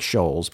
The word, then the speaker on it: Scholes: It's S-C-H-O-L-E-S, and yes, I looked up how to say her name, because mm-hmm, it looks like Scholes, but it's right, Scholes, right, according to...